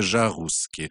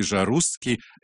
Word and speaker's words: Жарусский: И так они записали это в его документы, но по-французски это произносится Жарусский. И вот с тех пор так семья носит такую замечательную фамилию. Но все-таки мы будем его называть по-французски Жарусский.